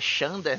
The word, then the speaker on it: Xander: Aí, no, em inglês, a pronúncia do nome dele é, é Xander, né? Parece com Xander, assim.